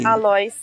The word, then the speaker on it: Alois: Aliás, Eloy. É como é que fala isso aí? – Alois.